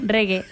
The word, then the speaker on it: reggae: ¿Cómo se dice al final? – Reggae. – Reggae. Hoy hemos abierto en las tardes con música de Bob Marley.